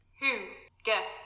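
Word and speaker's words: hygge: Then what's H-U-G-G-A? – Huga? – Okay. Oh, the word hygiene, well, I said hygiene, it's pronounced huga, is usually translated as coziness. But then what's H-U-G-G-A? – What is that supposed to mean?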